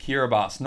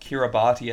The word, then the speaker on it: Kiribati: I believe it's pronounced Kiribati. – You want to lock that in? – Lock it in. – The answer is indeed Kiribati, not Kiribati as it's spelled.